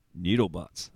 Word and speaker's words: NWDL bots: And let's see, NWDL bots. Pronounced right. Noodle bots. There we go. That's it. Why would you... Oh, it really winds me up that when like you want something to be pronounced in this way, noodle bots, and then you have a random word.